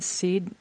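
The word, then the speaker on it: sídhe: How do you pronounce them? Seed? – Well, that's an interesting question. The modern Irish pronunciation is she. – She? Okay. – She. And you know, you've heard the word banshee?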